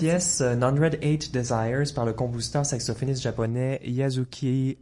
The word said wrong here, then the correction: Yasuaki: euh, Shimuyuki. Euh, est-ce que vous pouvez nous en... Oui, j'ai peut-être mal prononcé son nom. Est-ce que vous pouvez nous en dire un mot? Yasuaki Shimizu. Oui, ben en fait, c'est un, un jazzman japonais très très connu.